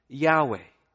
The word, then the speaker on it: Yahweh: And in the Hebrew, it's probably pronounced something like Yahweh.